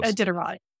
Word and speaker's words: Iditarod: I did a tarot. Did I say it right? – Very close. I did a rod.